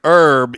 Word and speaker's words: herb: And so heavy emphasis on the H, but when you say herb, you leave the H off.